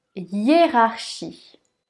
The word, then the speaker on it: hiérarchie: comment on prononce ce mot Il se prononce hiérarchie. Hie-rar-chi. Le H ne se prononce pas. Yé. Rare. Les deux R sont bien prononcés.